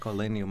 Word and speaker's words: legno: Like lately I've been interested in legno. – Yeah, that's how you say it, legno.